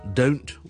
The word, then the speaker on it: don't: OK，唔系我哋东南西北嗰个东，而系我哋读成好似 do OK， do 跟住再加个 n 先至 t，咁就读成咧系。 Don't 有一个元音上的转变嘅，do 跟住先至 end。 Don't， so I don't want it， I don't know， OK 就系 don't。